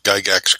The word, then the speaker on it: Gygax: And don't worry, John, you pronounced Gygax correctly.